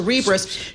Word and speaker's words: Cerebras: Like Cerebral. Yes. So Cerebras shares soaring.